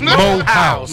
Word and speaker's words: Morehouse: Morehouse, a Morehouse man. – Went to Mohouse. – He said you ain't saying it right. – Mohouse. – It's Mohouse.